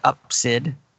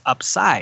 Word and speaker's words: UPSYD: And I've kind of reversed the order and created an acronym, UPSYD, which is UPSYD. And it looks like UPSYD, but it's UPSYD. And the reason why it's pronounced that way is because once you truly understand this, you give your campaigns or your client campaigns the absolute most upside potential right out of the gates.